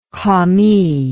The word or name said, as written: commis